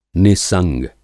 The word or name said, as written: नि:संग